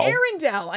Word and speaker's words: Arundel: Arundel.